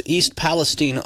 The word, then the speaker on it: East Palestine: Yeah, they talked about this town of East Palestine, Ohio. That's how you say it.